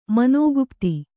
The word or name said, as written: ಮನೋಗುಪ್ತಿ